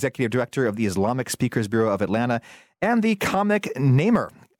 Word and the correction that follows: Nimmer: Am I saying that right, by the way? – Close. It's Nimmer. It's like Nimmer. It's like Simmer, but hotter.